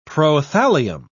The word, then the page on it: prothallium: British English